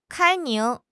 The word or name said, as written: 开明